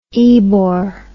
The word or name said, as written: Ebor